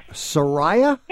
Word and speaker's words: Soraya: Soraya? – Yes. – How do you spell it? – S-O-R-A-Y-A. – A-Y-A.